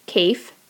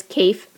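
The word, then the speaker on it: Kaffe: And he said the way to remember how to pronounce his name, although he says it makes no difference to him, is if you think of the words safe asset. So safe, Cafe, asset, facet. So it is Cafe Fassett.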